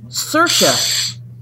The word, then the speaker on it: Saoirse: S-A- O-I-R-S-E. – Oh, yeah. – It's Sarah. Sarah. – Oh, wait. Here's the... Okay, so it's... Saoirse.